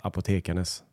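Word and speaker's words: Apotekarnes: Jag orkar inte läsa upp allting, men när jag läste så är det ungefär som att det är bara för att det är en gammal stavning. Så det ska uttalas apotekarnas.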